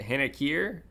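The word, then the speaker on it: Hen Ichaer: How do you say that? Hin Akir? Is that how I say it? H-E-N-I-K-I-E-R? – Yes. Yep.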